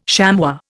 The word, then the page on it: chamois: Indian